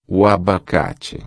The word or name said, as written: abacate